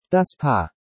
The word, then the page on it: Das Paar: Deutsch